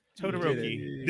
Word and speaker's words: Todoroki: Todoroki.